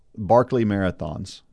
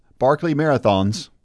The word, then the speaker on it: Barkley Marathons: Well, the answer is Barkley Marathons, mm-hmm. and it's it's pronounced that way too. It is Barkley Marathons, not Barkley Marathon.